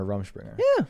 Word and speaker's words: Rumspringa: So Miley Cyrus is on her Rumspringa. – Yeah. – That's how you say it.